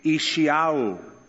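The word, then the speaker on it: Yeshayahu (ישעיהו): His Hebrew pronunciation would be Yeshayahu.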